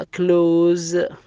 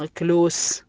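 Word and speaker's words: close: يوز يستخدم معناها، نوز معناها الأنف، الكلمة اللي بعدها كلوز ما تنطق كلوس بل تنطق كلوز، الكلمة الأخيرة تشيز، هنا نطقنا حرف الإس بحرف الزاء، أتى قبله وبعده حروف متحركة.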